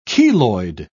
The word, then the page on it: keloid: British English